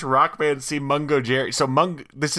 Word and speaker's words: Mungojerrie: I have confirmed the cat's name is pronounced Mungo Jerry